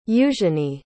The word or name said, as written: eugenie